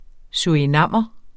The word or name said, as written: surinamer